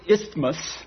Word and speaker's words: isthmus: Paul comes down from Athens, goes across this isthmus. Did I pronounce that right? I-S-T-H-M-U-S, isthmus, which is a little land bridge down onto a peninsula.